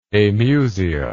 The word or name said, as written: amusia